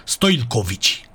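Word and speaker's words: Stojilković: Filip Stoilkovici. Stoilkapovici, deci așa se pronunță, Stoilkovici, cu L la mijloc,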